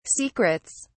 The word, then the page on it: secrets: British English